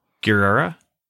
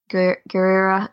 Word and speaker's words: Gurira: Danai Gurira. – Gurira.